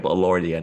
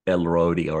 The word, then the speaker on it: Elordi: I just realized it's Jacob Elordi and not Elrodi or whatever it is I was calling him.